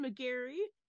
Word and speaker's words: McGarry: I am joined today by not one, but two guests, Dan McGarry and Travis Scherset.